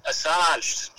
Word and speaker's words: Assange: Assange.